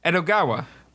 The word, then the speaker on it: Edogawa: E-D-O... – Eduardo. It's Eduardo. – G-A-W-A. – Oh, Edogawa? – Edogawa.